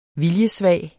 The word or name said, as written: VILJESVAGE